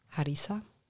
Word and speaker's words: Harissa: Weil in diesen Rezepten, die in dem Buch drin sind, ist sehr viel Harissa. – Ja. – So spricht man es aus, ja? – Genau.